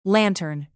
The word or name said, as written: lantern